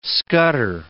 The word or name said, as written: scutter